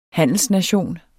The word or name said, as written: handelsnation